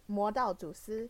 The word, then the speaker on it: Modaozushi: It's Modaozushi, and it's originally a web novel created in October 2015 by the author, who is Mo Xiang Tong Xiu.